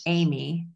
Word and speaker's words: Eighme: My maiden name was Amy, but it's spelled E I G H M E. And it was kind of similar. Like nobody ever got it.